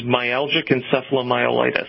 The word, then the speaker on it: myalgic encephalomyelitis: The ME is myalgic encephalomyelitis.